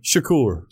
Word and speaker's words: Shakur: Shakur.